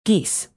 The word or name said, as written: geese